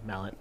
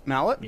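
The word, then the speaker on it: Mallat: The CEO, Mallet, he says, well, that's his last name, Mallet. – Mallet? – Yeah, Mallet. – I like it. – M-A-L-L-A-T, I can pronounce that.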